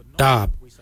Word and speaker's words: dhaab: Today we will look at, for knife we say darb. Darb is spelled D-H-A-A-B.